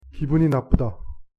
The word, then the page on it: 기분이 나쁘다: Korean